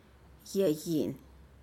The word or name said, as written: yeyi'in